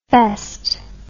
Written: fest

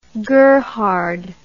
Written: gerhard

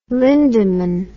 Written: lindemann

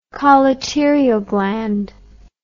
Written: colleterial gland

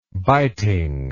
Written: bitingness

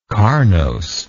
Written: carnosity

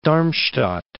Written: Darmstadt

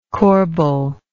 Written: corbeil

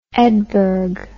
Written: Edberg

